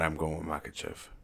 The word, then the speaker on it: Makhachev: Is that how you pronounce it? Makhachev. Yeah. Islam Makhachev. – Sounds about right.